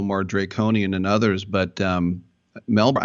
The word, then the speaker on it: Melbourne: I know you're from Melbourne, and I know that you're not supposed to pronounce the R, right? Melbourne? – Melbourne. Yeah, Melbourne.